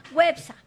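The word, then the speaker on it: Güepsa: ¿Cómo se dice a los que nacieron en Huespa? Perdón, Huepsa. Websa. ¿Ah? Con diéresis.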